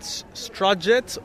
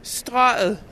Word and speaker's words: Strøget: Now let's get the correct pronunciation from my Danish wife. – Strøget. – That doesn't seem to make sense at all. – No, it doesn't, because there's a G in there, but you can hardly hear it.